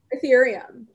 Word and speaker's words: Ethereum: ETH, Erythium? – Ethereum. Ether. Yeah, like the element, the mystical element of ether.